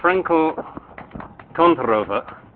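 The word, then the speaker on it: Frenkel–Kontorova: Okay, so Franco Kontorova, as Pavel just told me I should pronounce it, model.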